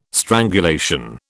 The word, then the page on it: strangulation: British English